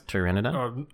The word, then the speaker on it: Pteranodon: Pteranodon? With a silent P? – Old dinosaur is a bit redundant.